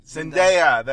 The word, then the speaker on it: Zendaya: Zendaya, that's what it is.